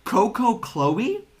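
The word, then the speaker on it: Coucou Chloe: I feel like I'm going to butcher her name, but I believe it's like, Coco Chloe? But it's like C-O-U-C-O-U, Chloe.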